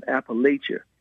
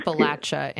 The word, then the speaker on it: Appalachia: I mean, the little children in Appalachia need education and it's pronounced healthcare. Appalachia in Appalachia, people, as a proud Appalachian.